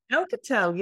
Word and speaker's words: Alcatel: Is it Alcatel? – Alcatel, yes.